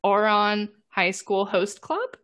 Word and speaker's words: Ouran High School Host Club: Ouran High School Host Club.